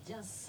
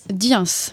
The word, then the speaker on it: Dyens: Ça s'écrit D-Y-E-N-S. Donc, concerto métis et tango en sky. Comment ? Diens. Diens.